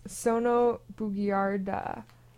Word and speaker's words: Sono Bugiarda: That was Sono Bugiarda. That's how you say it in Italian.